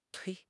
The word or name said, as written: tui1